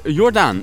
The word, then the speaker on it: Jordaan: En vanaf vrijdag 5 juni kunnen we een heleboel duurzame designproducten gaan bewonderen in het hartje Jordaan. Zeg ik dat goed, Robert? – Ja, dat zeg je goed.